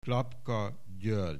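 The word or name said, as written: Klapka György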